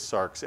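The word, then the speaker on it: sarx (σάρξ): The word that we would pronounce in Greek is sarx, S-A-R-X.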